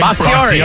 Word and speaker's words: Bakhtiari: Bakhtiari.